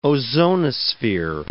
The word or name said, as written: ozonosphere